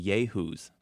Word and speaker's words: Yayhoos: Yehoos. – The Yehoos, that's spelled Y-A-Y-H-O-O-S, are a bunch of Yahoos whom you may or may not know individually.